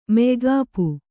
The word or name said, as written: ಮೆಯ್ಗಾಪು